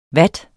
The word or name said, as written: watt